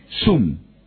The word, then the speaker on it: SUM: ¿Zoom se escribe Zoom? – No, S-U-M, Zoom.